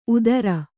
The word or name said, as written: ಊದರೆ